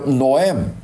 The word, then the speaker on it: Noem: 另外一个呢，就是国土安全部的部长，这个人呢，我一跟大家读过，叫做 Christy Norm 啊，这个 Norm 这个拼法是 N O E M。所以叫 Noam 大概是要读成。